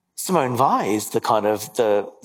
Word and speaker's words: Weil: She was her surname is spelled W-E-I-L, but pronounced Weil.